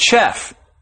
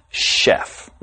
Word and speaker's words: chef: And why this word is pronounced chef and not chef, that's because the word chef came from the French language. Hence the pronunciation chef.